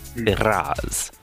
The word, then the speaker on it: Firaz: Let the R roll. – Firaz. – There it is.